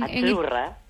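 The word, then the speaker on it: Azzurra: Achurra. – Achurra. Mira, ¿ves? – Achurra. – Es que... – Sí, como porque pizza. La zeta no es la zeta como es decimos en catalán tan sonora, no.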